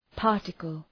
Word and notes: particle: {‘pɑ:rtıkəl}

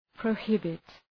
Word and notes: prohibit: prəʋ’hıbıt

